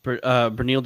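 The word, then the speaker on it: Beneil: Let's go back to how do you pronounce it? Benil? Banal? – Benil Dariush.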